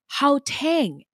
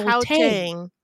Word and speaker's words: Gauteng: Gauteng is pronounced, we got this wrong, so thank you so much. It's pronounced Gauteng. It actually has a more throaty H sound. – Huh. Gauteng.